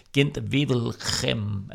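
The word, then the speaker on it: Gent-Wevelgem: Og så kigger vi frem mod de næste belgiske klassikere, nemlig E3 og Gent Wevelgem. Er det ikke sådan, det udtales, Kim? – Jo.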